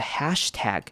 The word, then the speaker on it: hashtag: First person became the first person to use the hashtag. Hashtag 呢也是台湾人非常容易念错的字。那为什么呢？因为它中间两个 a 呢，大家很容易念成其他的念法。但是标准的念法是把两个 a 倒念成短母音 a 的声音，a 的声音。所以我们会念 hashtag, hashtag. Tag，那短母音 A 跟长母音 A 差别就是短母音 A 是要把嘴巴稍微往下，把嘴巴往下拉长，hash 把嘴巴张大，而不是往旁边的方向，而是往上下的方向。